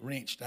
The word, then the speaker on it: wrenched: We're all dirty until we get wrenched out. Now, for you Californians and you Yankees in here, wrenched is, it's the southern version of rinsed. And it's spelled W-R-E-N-C-H-E-D, wrenched.